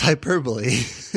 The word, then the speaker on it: hyperbole: Did you say hyperbole as a joke, or do you think that's really how it's pronounced? – That's how it's pronounced? – It's hyperbole.